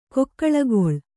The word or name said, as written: ಕಿಕ್ಕಿಲಿಕಿಲಿ